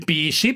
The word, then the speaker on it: Pisi: Ho fondato il Pisi, partito super sovranista italiano, PSSI si pronuncia.